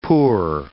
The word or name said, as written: poor